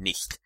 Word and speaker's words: nicht: I think perhaps a slightly better example is the CH sound in German, which is pronounced like in the word nicht. So it's pronounced as a ch.